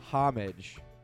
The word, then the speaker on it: homage: Homage. You don't pronounce the H.